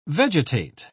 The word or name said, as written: vegetate